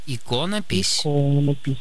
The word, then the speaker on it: иконопись: Абсолютно все словари фиксируют ударение в данном слове только на первом слоге, Ириночка, представляете? Поэтому правильно говорить иконопись, иконопись.